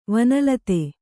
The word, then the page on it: ವನಲತೆ: vana late